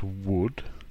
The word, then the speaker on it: would: It's pronounced would. It's got the u vowel sound, like book or could. And it's a homophone with the material would. Now, this table is made out of wood, but different spelling, W-O-O-D, and different meaning, but it sounds the same.